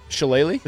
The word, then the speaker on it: shillelagh: How did you pronounce that? – Shillelagh? – A shillelagh. Like the golden shillelagh that they give away for the USC Notre Dame game.